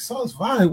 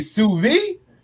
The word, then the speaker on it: sous vide: SOUSVIDE. So I'm you know, but it's. I believe it's French. Don't really? – On that, but something like that. So it's pronounced sous vide.